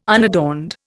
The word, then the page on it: unadorned: Indian